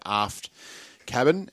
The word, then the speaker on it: Aft Cabin: Now, man tells us they're Aft Cabin, as in you don't want to be too daft about things. So Aft Cabin is the pronunciation.